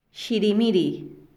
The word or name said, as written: xirimiri